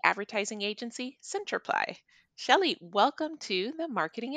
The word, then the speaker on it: Centriply: Our name, Centriply, it's pronounced like clarify, amplify, simplify.